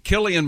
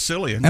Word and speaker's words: Cillian: Killian Murphy, and I just learned over the weekend that is the way he pronounces his name. – Okay. – I always thought it was Cillian.